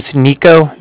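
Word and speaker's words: Niko: My name's Nico. That's N-I-K-O. I was calling with the Bank of Cardiff, and that's spelled C-A-R-D-I-F-F, as in Frank, so it's like car and then different.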